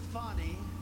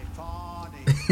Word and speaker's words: Fani: He worked with Fanny, even though it's spelled Fanny. F-A-N-I, Fanny.